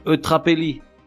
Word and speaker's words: eutrapélie: Prenons le téléphérique jusqu'aux cimes immaculées puis braquons le télescope sur les mots Eutrapelli en tienne équanimité, et jaculatoire. Eutrapelli, plus ou moins comme ça se prononce avec un E avant le L, nom féminin.